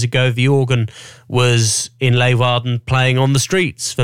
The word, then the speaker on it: Leeuwarden: I asked Niels Burkers from Holland to record me the pronunciation earlier. – Princeton Tune, Leeuwarden. – Oh yeah, so Princeton Tune, Leeuwarden. Say it loud and proud.